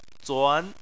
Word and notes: zuan: 2

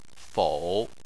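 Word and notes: fou: tone 3

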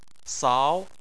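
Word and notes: sao: tone 2

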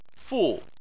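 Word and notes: fu: tone 4

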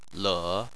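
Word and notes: le: tone 3

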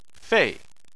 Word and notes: fei: 4

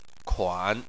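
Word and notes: kuan: tone 3

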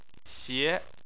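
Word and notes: xie: xie2; tone 2

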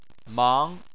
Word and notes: mang: 2

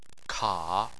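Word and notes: ka: tone 3

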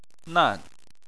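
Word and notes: nan: tone 4

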